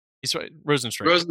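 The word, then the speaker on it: Rozenstruik: I don't know how to say this guy's name. Harinzino Rosenstrike. – Rosenstrike.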